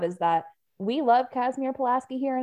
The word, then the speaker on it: Pulaski: I didn't know people knew Kazimierz Pulaski outside of like Poland and Illinois, but we have a town called Pulaski here, but it's pronounced Pulaski. Thank you. Because we're dumb.